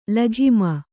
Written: ಲಗಾಮು

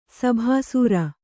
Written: ಸಭಾಸೂರ

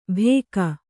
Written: ಭೇಕ

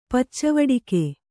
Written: ಪಚ್ಚವಡಿಗೆ

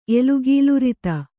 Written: ಎಲುಗೀಲುರಿತ

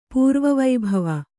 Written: ಪೂರ್ವವೈಭವ

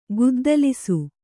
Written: ಗದ್ದಲಿಸು

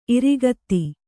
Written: ಇರಿಗತ್ತಿ

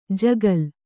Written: ಜಗುಲಿ

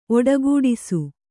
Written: ಒಡಗೂಡಿಸು

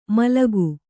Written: ಮಲಕು

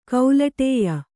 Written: ಕೌಲಟೇಯ

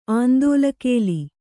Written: ಆಂದೋಲಕೇಲಿ

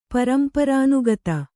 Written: ಪರಂಪರಾನುಗತ

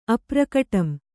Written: ಅಪ್ರಕಟಂ

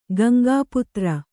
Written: ಗಂಗಾಪುತ್ರ

